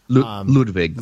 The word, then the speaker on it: Ludwig: Ludwig.